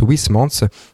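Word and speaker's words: Huysmans: La chronique d'Emma qu'on adore, où on voyage par l'odeur, et là c'est la suite du texte à rebours de Wismans. Donc moi je prononçais mal sur l'émission précédente, j'avais dit Wisman. Bon, il paraît que ça se prononce Wismans.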